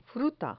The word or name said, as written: fruta